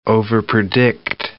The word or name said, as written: overpredict